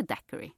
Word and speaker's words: daiquiri: Heter det daiquiri? – Ja.